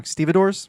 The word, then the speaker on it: stevedores: I always say this wrong. Stevedores. – Yeah, that's right.